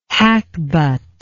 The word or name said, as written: hackbut